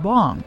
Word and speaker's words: Bang: Bang, spelled B-A-N-G, is pronounced Bong in Korean, but he says it's easier for Americans to remember Bang, especially when the PA announcer yells his name after he hits a long three.